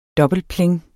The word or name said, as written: dobbeltpling